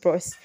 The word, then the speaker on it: Prost: I'm pretty sure you're not supposed to pronounce the T at the end of Prost, but I just pronounced it, sorry.